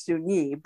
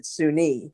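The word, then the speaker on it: Soon-Yi: Now, those children, I believe all of them came from Vietnam, including Suni, whose name I thought was Suni, but they pronounce it Suni, the woman who married Woody Allen, the oldest of the adopted children.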